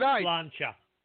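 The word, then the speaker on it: Sláinte: Now it's pronounced Slancha.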